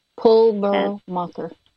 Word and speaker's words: Pulvermacher: P-U-L-E-R-M-A-C-H-E-R. – Okay, C-O-L-E-M-A-C-H-E-R, Pulvermacher? – Pulvermacher. P-U-L, P like Peter, P-U-L.